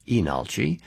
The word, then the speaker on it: Leenalchi: Again, it looks like Leenalchi, but pronounced Inalchi.